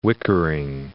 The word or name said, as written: whickering